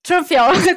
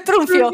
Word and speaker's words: Trunfio: Trunfio, Trunfio, Alessia Trunfio.